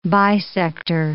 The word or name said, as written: bisector